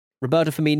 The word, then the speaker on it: Roberto Firmino: Roberto Firmino, Norbert Solano. You got to just take it one syllable at a time.